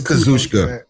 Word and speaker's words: Kazuchika: And I never try to pronounce his first name, so I get it's Kazuchika. That's not that hard.